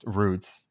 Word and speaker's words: routes: Also, now good to see that routes, pronounced roots, as we pronounce it properly over here in the UK.